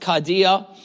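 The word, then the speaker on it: cardia (καρδία): This word that we say heart, that's been translated to heart in English, comes from this Greek word pronounced cardia.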